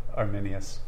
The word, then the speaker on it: Arminius: Arminius.